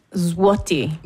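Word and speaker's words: złoty: Ich glaube, es sind Slotti, oder? – Zloty. Ausgesprochen. – Ja. – Das ist so ein L mit so einem Spiegel.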